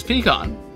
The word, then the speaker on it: pecan: There is only one way to say pecan, and that is pecan. And that is the way I say it. That is the correct way to say it.